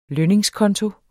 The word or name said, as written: lønningskonto